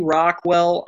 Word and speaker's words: Rachwal: Yeah, they can get a hold of me by looking up my name, Joey Rockwell, on any social media. So it's spelled RACHWAL.